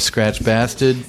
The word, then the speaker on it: Skratch Bastid: here we are in the kitchen of Skratch Bastid.